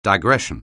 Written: digression

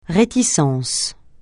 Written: réticence

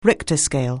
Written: Richter scale

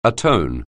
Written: atone

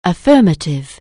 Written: affirmative

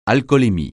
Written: alcoolémie

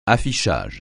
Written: affichage

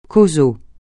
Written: causal

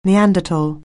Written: Neanderthal

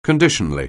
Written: conditionally

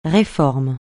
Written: réforme

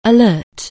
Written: alert

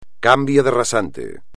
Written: cambio de rasante

